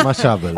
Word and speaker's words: Mashable: Así estuve pronunciando un rato, Mashable. – Mashable.